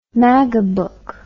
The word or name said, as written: magabook